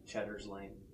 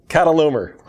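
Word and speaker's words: Chedorlaomer: Cheddar's Lane. – That is not how you say that. – Kedorlaomer.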